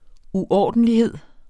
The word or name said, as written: uordentlighed